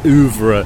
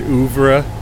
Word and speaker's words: oeuvre: I think it's between Clockwork Orange and Shining, if I'm not mistaken, for the Kubrick oeuvre, if I may use that word, oeuvre. Is that how you say it, oeuvre? – Oeuvre. Oeuvre. Wah!